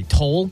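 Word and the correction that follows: Tolle: Oh, Tolly.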